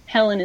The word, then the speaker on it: Helen: That's H-E-I-D-I-H-E-L-E-N. Helen is my middle name, so I just like the sound of that double H.